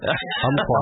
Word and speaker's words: Umpqua: The P is silent. Umpqua.